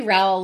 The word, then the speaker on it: Rowell: So it's L-A-I-N. Thank you. L-A-I-N-I-E. And I don't even know if I just spelled it correctly. Rowell, like Powell.